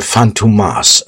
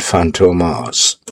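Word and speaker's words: Fantômas: Ja, will you, oh Gott, Chase Fantomas, ja. Oder Fantomas wird das, glaube ich, ausgesprochen. Das O mit den Tütelchen oben drauf.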